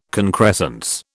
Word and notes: concrescence: British English

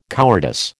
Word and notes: cowardice: American English

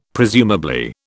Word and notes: presumably: British English

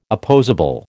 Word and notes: opposable: American English

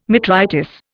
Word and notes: metritis: Indian

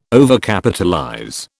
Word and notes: Overcapitalize: British English

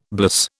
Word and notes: bliss: British English

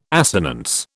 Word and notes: assonance: British English

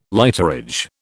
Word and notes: lighterage: British English

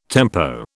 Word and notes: tempo: British English

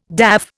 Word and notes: daff: Indian

